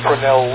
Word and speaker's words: Fresnel: Okay, the people who know French pronounce it Fresnel. Although it's spelled Fresnel, they pronounce it Fresnel.